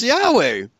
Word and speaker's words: Yahweh: You pronounce it, that J in English, as Yah, just as you would pronounce Yahweh. Okay, and then go ahead. – Exactly in the same way as it's pronounced in Hebrew.